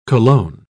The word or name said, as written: cologne